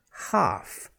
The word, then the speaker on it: half: In each of them, the L is silent. Okay, half. Half the cake is better than no cake. It's spelled H-A-L-F. We don't pronounce the L. Half. I will talk to you tomorrow. T-A-L-K, very common word.